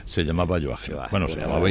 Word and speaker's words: Joachim: Bueno, debo decir que se dice Joachim. – Yo esta mañana he hecho alguna prueba de sonido para ver cómo se pronuncia en alemán y es Joachim. Dicen Joachim.